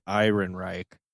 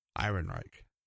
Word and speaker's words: Ehrenreich: Well, one thing I found, we've been mispronouncing Alden's name. According to IMDb, it's pronounced Ironreich. – Ironreich.